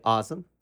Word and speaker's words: Azam: It's actually awesome. Like Awesome. Awesome.